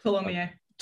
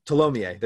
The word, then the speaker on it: Tholomyès: Tholomyès. – Tholomyès, there it is.